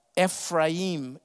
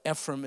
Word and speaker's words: Ephraim: And verse 17, Ephraim is how you pronounce it, but Ephraim is okay for you Texans.